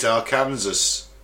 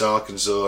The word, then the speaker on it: Arkansas: Because it looks like you should say it Arkansas. – That's how it reads. – That's how it reads. Okay. But it's not, it's Arkansas, and I've ever looked at the word.